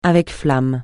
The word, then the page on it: avec flamme: francais